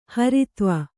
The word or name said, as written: ಹರಿತ್ವ